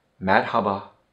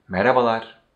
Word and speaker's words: Merhaba: Merhaba. Merhaba. Merhaba. Merhaba. Or if you say a little bit faster, you will pronounce it like merhaba. Merhaba. Or you can say, like I say, merhabalar.